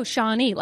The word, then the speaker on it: Shawnee: Oh, Shawnee, like the Native American tribe.